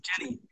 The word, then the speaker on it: Ginny: So we see it with Dobby, but then just at the end of chapter 3, we see it with Ginny or Ginny. How do you say that? – Yes, Ginny from Ginevra.